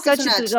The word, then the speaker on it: Xochitl: Xochitl Gómez, correcto.